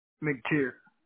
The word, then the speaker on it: McTier: McTeer. – How do you spell it? – M-C-T-I-E-R. – E-I-E-R.